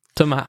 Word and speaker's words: Thomas: Thomas is, okay. Is usually the French pronunciation, yeah.